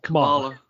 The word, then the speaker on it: Kamala: I probably just butchered her name. – Yeah, Kamala. – Kamala. Yeah, that's it.